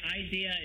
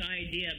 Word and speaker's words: Eyedea: But if you look at that, it's Idea, but it's spelled E-Y-E-D-E-A. – Okay. – Idea.